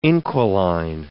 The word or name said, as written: inquiline